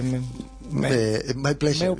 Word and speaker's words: my pleasure: Ah, fue mi pleasure, ¿cómo se dice? – My pleasure, exactamente.